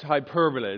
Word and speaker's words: hyperbole: It's actually not pronounced that way, but it's it's, it's hyperbole.